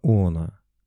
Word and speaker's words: Она: Приток Кинева называется Она, с ударением на первый слог.